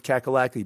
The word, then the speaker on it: Cackalacky: Hard to square, but this place is called North Cackalacky by a lot of people that like it, so it rhymes with wacky.